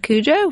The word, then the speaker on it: Cujo: Aaron and Meridian said Cujo.